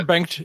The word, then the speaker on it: Bengt: I'm pretty sure Bengt is correct. – Bengt. – Yeah, Bengt.